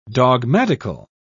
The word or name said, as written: dogmatical